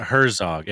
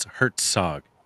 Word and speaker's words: Herzog: And it's not Herzog, it's Herzog. And it, Z's in German are pronounced like TZ, and the W in German is pronounced like a V. So it's Werner Herzog.